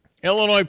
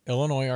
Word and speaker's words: Illinois: It's a battle of the silent S. Illinois, Arkansas, Des Moines. Come on. – Oh. – Illinois, Arkansas. So there's a little S here, but not really at the end.